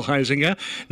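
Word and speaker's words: Huizenga: Congressman Bill Heisinger, I said it incorrectly, 2nd District Congressman Bill Heisinger. Now I got it right.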